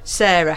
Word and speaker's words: Sara: Now, Sarah, how is it spelt? Would you like to talk about your name and how it's spelled? – Yes, it's no H on my name. I'm Sarah, S-A-R-A.